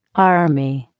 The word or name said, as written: army